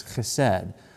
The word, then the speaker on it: chesed: You got to like, if you're going to say that right, it's chesed.